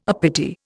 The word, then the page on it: uppity: Indian